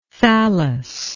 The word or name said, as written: thallous